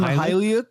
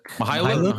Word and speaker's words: Mykhailiuk: Ban- uh, uh, Svi, what's how do you say Svi's last name? I don't know Svi's last name. Mykhailiuk. Mykhailiuk. Mykhailiuk. – Mykhailiuk. Okay. Mykhailiuk. Okay.